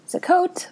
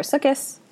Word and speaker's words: Sukkot: and Rosh Hashanah and Yom Kippur and Sukkot, or Sukkot, if you are like me and pronounce it that way.